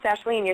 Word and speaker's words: Aisling: But it's pronounced Ashleen. You're saying it right. Thank you, because people say it wrong all the time. But it's spelled, the first name is spelled A-I-S-L-I-N-G.